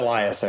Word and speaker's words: Elias: Now, did I pronounce that correctly? – Yeah, Elias, actually. – Elias, sorry. Yeah, I should have asked before I started recording.